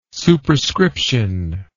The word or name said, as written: superscription